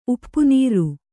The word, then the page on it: ಉಪ್ಪುನೀರ್: uppu nīr